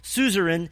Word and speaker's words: suzerain: Suzerain, the suzerain nation, and it's spelled, if you're interested, S-U-Z-E-R-A-I-N, like Suzy Rain.